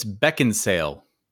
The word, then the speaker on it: Beckinsale: And Dan, just side note, you keep pronouncing Kate's last name as if there's a D in it. – Mm-hmm. What is it? – It's Beckinsale.